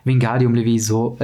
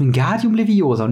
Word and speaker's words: Wingardium Leviosa: Das heißt nicht Wingardium Levioso, es heißt Wingardium Leviosa und nicht Leviosa. So.